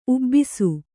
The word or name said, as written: ಉಬ್ಬಿಸು